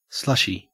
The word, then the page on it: slushy: Australian English